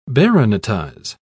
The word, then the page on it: baronetize: British English